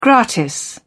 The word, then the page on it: gratis: British English